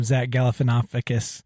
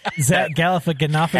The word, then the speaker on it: Galifianakis: That reminds me of Between Two Ferns when Zach Galifianakis. Boy, I butchered that, didn't I? Zach Galifianakis.